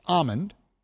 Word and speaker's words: almond: word almond, which is spelled but not pronounced with an L in it. It's almond.